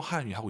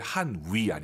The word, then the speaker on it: 汉语: 지금 저희 둘이 教 한유 하고 있는 거잖아요. – 没错. – 네, 그렇습니다. 한유. 중국어 발음하실 때 한위 아니고 YU 발음은 입술 동그랗게 말아서